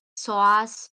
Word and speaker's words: psoas: I don't know how it's pronounced. It's psoas, PSOAS. Yeah, it's a muscle.